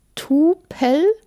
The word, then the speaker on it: Tupel: Tupel? – Tupel, ja. – Das Wie schreibt man das? – T-U-P-E-L.